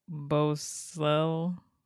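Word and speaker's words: bouclé: Bocelle. – The way you pronounce that, killing it. – Yeah. – B-O-U-C-L-E with an accent over it.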